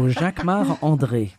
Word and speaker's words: Jacquemart-André: Jacquemart-André. You say it like André. A Frenchman. – Jacquemart André. Of course, because I'm French. So Jacquemart André.